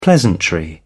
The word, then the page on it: pleasantry: British English